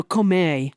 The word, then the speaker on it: Comey: Comey? – No, Comey.